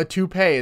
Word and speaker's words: toupee: A toupe? You want me to rock? – Well, a toupee is how it's pronounced, but yes. – You want me to go full George Costanza and rock a toupe? – Well, again, a toupee, but yes